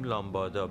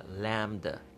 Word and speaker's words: lambda: تلفظش اون چیزی که ما میگیم میگیم لامبادا برخی از ما البته ولی آنچه که در حقیقت گفته میشه به صورت اصلی لامدا لامدا L A M B D A لامدا حالا ما در فارسی میتونیم بگیم لامدا لامدا یا لامدا لامدا اون بی هم در